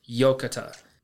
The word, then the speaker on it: yokatta: And the Japanese word for thank goodness is yokata. And yokata is spelled Y-O-K-A-T-T-A.